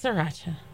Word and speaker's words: Sriracha: He's trying to pronounce it like it's spelled. – Sriracha. – It's Sriracha. You know it. You know this. – Is this Sriracha? – Is this extra R a typo or something? – No. – The R is silent.